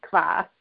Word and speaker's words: kvass: It's called kvass, I believe. – Kvass, yep. – And, yeah, well, what is that and how is that made? – Yeah. So the kvass is this is beet kvass.